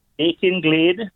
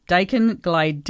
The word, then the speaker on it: Dakin Glade: Now, your address, 3 Dakin or Dakin? How do we pronounce the... – Dakin Glade. – Dakin Glade, D-A-K-I-N.